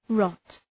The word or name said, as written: rot